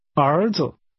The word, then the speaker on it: 儿子: Sons is pronounced with rising and falling rising tones. Say, sons. – 儿子。